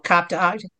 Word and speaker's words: Cap d'Agde: Okay, that's how you spell it, Cap d'Agde.